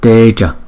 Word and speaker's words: teja: Còn chữ trước chúng ta đọc là teja, teja. Chữ A nó không có dấu gạch ngang trên đầu, chúng ta đọc là a, a, a, teja, teja.